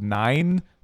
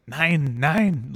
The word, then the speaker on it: Nein: This segment is called Nine, but it's spelled N like nine. Like, spell it. It's like spelled like what? – N E I N. So it's the German word for no. Nine. It's German for no.